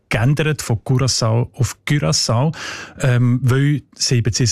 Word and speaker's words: Curaçao: Curaçao heisst die Insel, habe ich gelernt. – Sorry? – Mit Ü, genau.